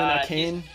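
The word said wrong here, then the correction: Achane: I think it's pronounced A-Chain.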